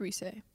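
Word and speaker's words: Reiss: In the book written by Mike, I think it's pronounced Rice, or Reese. Mm-hmm. It's a great book about Reese. The Simpsons. It's a wonderful book. – It's Reese.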